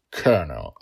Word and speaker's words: colonel: And may I just say that with my accent, it is very hard to say colonel. Also, to think, I always thought I said that wrong, because there's no R in colonel. It says colonel. There's no R there.